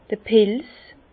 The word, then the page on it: d'r Pils: Bas Rhin; Herrlisheim